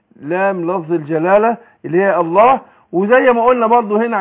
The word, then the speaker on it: الله: يعني بنطق بعد النون ايه لام ان يبقى الهمزه فين، لكن لو نطقت الله اقول الله يبقى اثبتها اثبت الهمزه فين في اول الايه الكلام اقول الله، لكن في وسط الكلام اقول ان الله سقط.